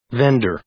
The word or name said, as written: vender